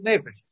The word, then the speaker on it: нефиш: Правильно говорить нефиш. А в посуке упоминается нефиш.